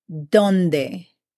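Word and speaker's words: dónde: Where in Spanish is said donde. Donde. And is spelled D-O-N-D-E.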